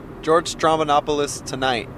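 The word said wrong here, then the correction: Stroumboulopoulos: Am I still saying it right? Stramanopoulos. – Strombolopoulos. – You know, I put an N in there. I went Stramanopoulos.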